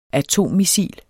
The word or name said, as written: atommissil